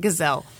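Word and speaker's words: Gisele: Gazelle.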